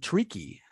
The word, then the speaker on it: Taraji: Uh, I think it's Tariq Henson is her name, um, and Taraji Henson. I guess is how you pronounce her first name.